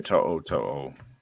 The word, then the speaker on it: To'o: Is it, how's that pronounced, the rookie? – Henry To'o.